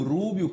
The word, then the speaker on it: Rubio: Marco Rubio, como você bem disse, é americano, mas é também cubano e por isso essa é a pronúncia certa, meu querido.